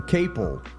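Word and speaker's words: Caple: Steven Kaplan. Is that his name? Let me get his name right, please. Capel. Yeah, Steven Capel.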